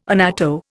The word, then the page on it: annatto: Indian